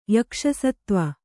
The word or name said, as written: ಯಕ್ಷಸತ್ತ್ವ